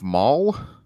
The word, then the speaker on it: Mawle: Joseph Mall M A W L E as uh, Adam.